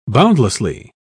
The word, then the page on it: boundlessly: British English